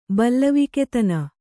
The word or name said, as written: ಬಲ್ಲವಿಕೆತನ